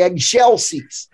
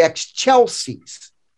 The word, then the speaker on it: excelsis: And don't forget, by the way, if you're singing this song in choir, do not say excelsis. Say excelsis. That's the correct choir pronunciation for it.